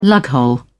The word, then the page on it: lughole: British English